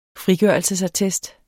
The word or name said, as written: frigørelsesattest